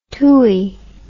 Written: tooie

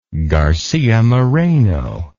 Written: garcia moreno

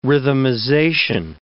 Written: rhythmization

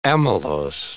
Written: amylose

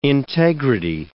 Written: integrity

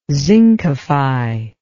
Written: zincify

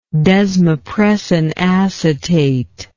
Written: desmopressin acetate

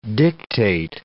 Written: dictate

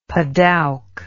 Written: padouk

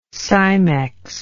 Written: cimex

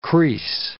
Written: crease

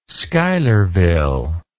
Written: schuylerville

